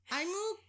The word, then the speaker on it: Aimo: Well, that's exactly what Aimo. Nope, I said it wrong. I even wrote down phonetically how to say it. Aimo Koivunen did. – Aimo Koivunen. – Koivunen. But it's spelled A-I-M-O.